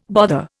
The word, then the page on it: bother: Indian